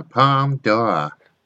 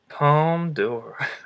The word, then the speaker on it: Palme d'Or: You have to say it like the pronunciation, though. Palme d'Or. – Palme d'Or.